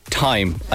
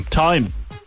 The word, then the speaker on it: Thyme: Wild Mountain Time. I think we're going to... – I'm going to say... – Pronounce it Time. – Time. Okay. – You're saying it's Time, not Thyme.